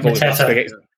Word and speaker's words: Mateta: Is it Mateta? I always get his name. – Yeah, Mateta.